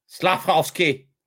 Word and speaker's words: Slafkovsky: Yuri Slavkowski commence. Bon, je pense qu'on l'a trouvé comment? Yuri Slav. Faut tu le prononces le F. Slavkowski.